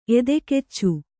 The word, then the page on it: ಎದೆಕೆಚ್ಚು: edekaccu; Kannada